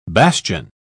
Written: bastion

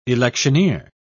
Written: electioneer

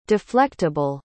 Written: deflectable

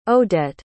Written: odette

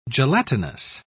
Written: gelatinous

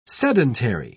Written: sedentary

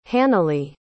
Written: hannalee

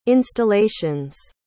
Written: installation's

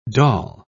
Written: doll

